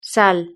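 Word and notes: sal: Spanish (Spain)

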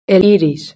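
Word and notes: el iris: Spanish (Spain)

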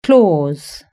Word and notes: claws: British English

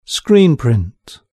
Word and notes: screen print: British English

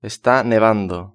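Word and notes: esta nevando: Spain

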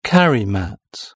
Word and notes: carry mat: British English

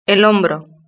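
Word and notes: el hombro: Spanish (Spain)

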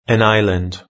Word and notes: an island: British English

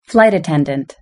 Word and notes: flight attendant: British English

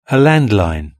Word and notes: a landline: British English

